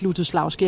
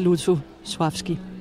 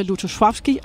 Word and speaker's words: Lutosławski: Ja, altså de fleste her på bagscenen siger faktisk Lutosławski, har jeg lagt mærke til. Men altså når jeg er i selskab med polakker, så siger jeg det i hvert fald ikke. Fordi der hedder det Lutosławski. – Der er den der lille streg over det andet L, som betyder, at man skal udtale det mere som V.